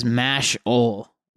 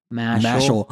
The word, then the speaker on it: Mashle: It is Mash-ole. Like, Mash-ole. – Like, instead of muscle. – Mashle.